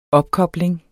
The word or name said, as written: opkobling